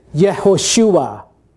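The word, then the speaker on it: Yehoshua (יהושע): There's a longer version, which is Yehoshua.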